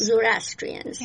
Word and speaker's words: Zoroastrians: Zoroastrians, yes.